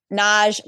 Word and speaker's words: Nagy: On TikTok, I'm at Toni Nagy, my last name is spelled N A G Y.